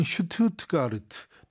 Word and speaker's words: Stuttgart: 지금 에스테론 모터즈는 a renowned car manufacturer, 유명한 자동차 제조업체로서 headquarter in Stuttgart, 우리식으로는 슈투트가르트, 독일로 발음하면 이렇죠.